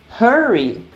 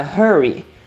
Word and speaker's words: hurry: Hurry tem um som de R, porque ele começa com H. In a hurry. Mas o outro, ele começa com R. E aí ele não tem um som de R com H, H. Ele tem um R mordido, rush, in a rush, ok?